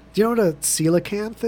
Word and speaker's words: coelacanth: Yeah, it's called coelacanth. Yeah, is how it's pronounced.